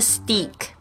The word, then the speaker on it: steak: 我们特别注意一下这个 steak 的发音，S T E A K，steak 就是牛排，它的这个 E A 中间 E A 的这个发音不是发 E 的长音，而是发这个 A 的双母音，所以我们要掌握好是 steak，不是 steak。